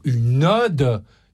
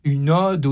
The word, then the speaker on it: ode: Ils ne savent pas prononcer, comme des millions de gens actuellement, le mot une ode. Une ode, ODE, ça se dit avec un O ouvert, et pas une ode, comme on entend dans le film, comme le prénom, avec, comme si c'était un O fermé.